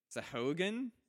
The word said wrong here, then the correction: Sahuagin: Sahuagin. – Sahuagin. – Sahuagin. There we go.